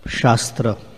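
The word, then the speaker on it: śāstra: There is a word, shastra. And there's also another word without the long A called shastra. In Sanskrit, shastra means scripture, and shastra means weapon. Just one makes a difference.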